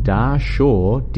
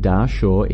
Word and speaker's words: Dahshur: And it's Bent Pyramid in Dahshur, D-A-H-S-H-U-R, Egypt, opens for first time in more than 50 years. So let me repeat this headline then. Sneferu's Bent Pyramid in Dahshur, Egypt, opens for first time in more than 50 years.